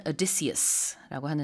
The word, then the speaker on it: Odysseus: 오디세우스, 영어로는 Odysseus라고 하는데요.